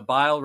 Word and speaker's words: Bile: The bile rhymes with vile, and that's exactly what he is.